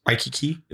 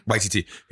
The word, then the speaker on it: Waititi: Waikiki? Am I saying that right? – Taika Waititi? – Waititi.